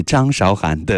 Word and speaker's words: 张韶涵: 来听自己称自己张韶涵，但是普通话标准读音应该是张韶涵的这位女歌手给我们唱出的，这是什么歌？歌声里，我们去看远方。